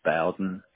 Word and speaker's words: Bowden: And then just for the account purpose, Kevin, your name, you spell it K-E-V-I-N? – Right. – And what's your last name? – It's Bowden, B-O-W-D-E-N. – Bowden, B-O-W-D-E-N.